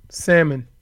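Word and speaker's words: Sammon: According to Will Salmon, which I'm probably pronouncing incorrectly, it's S-A-M-M-O-N. Simone? – Salmon.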